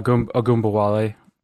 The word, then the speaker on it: Ogunbowale: Oh, Ogunbowale.